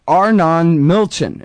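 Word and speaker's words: Arnon Milchan: Tell us all about Arnon Milchin.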